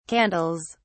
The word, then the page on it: candles: British English